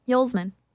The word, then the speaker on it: Hulsman: Oh, I thought it was Huselman. – Hulsman. – Okay. – Like Yule, but Hule.